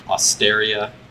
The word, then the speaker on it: Osteria: Osteria.